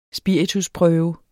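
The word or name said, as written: SPIRITUSPRØVE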